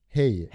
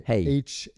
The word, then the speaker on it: hej: Hey. – H-E-J. – Yeah. So is J-Y, like pronounced as a Y. – It sounds like hey. Just like when you say hey in English, that's hello in Swedish.